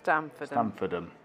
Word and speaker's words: Stamfordham: 2018 saw the peloton take on the race in Stamfordham. Stamfordham in Northumberland. You'll be able to tell me if I'm pronouncing that wrong. – Stamfordham.